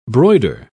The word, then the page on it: broider: British English